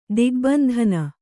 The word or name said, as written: ದಿಗ್ಬಂಧನ